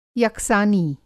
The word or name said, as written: यकसानी